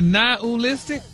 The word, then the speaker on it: nihilistic: Nihilistic? – Wow. – Stop putting the ooh in there.